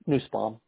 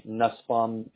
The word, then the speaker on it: Nussbaum: How do you pronounce that? – Nussbaum. – Nussbaum? Okay. I was going to say that, but I don't like messing up people's last names. Okay. – Oftentimes I'll say Nussbaum because phonetically it makes more sense for...